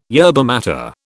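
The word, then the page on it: yerba maté: British English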